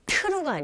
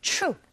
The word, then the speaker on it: true: true가 아니라 t 사운드와 r 사운드가 이렇게 맞물려 있는 경우에는 ch, ch, ch 이런 소리로 발음을 하게 되고 또 그렇게 들리게 되거든요. 그래서 발음하실 때 true가 아니라 true, true.